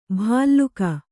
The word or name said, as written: ಭಲ್ಲೂಕ